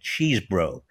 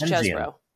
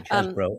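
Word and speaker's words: Chesebro: I was always pronouncing it Cheesbrough. I thought it was, he's from Wisconsin. – That's not wrong. – I think Cheesbrough is But I do think it's Chesbrough. – Yeah, Chesbrough.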